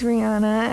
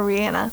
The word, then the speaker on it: Rihanna: We love Miss Rihanna as she, or Rihanna, sorry, excuse me.